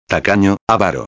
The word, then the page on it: tacano avaro: espanhol